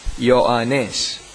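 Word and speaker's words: Ioannes (Ιωάννης): Do you know what is the Greek pronunciation of John? Ioannes. Everything that we put as J in Greek and Hebrew will be Y.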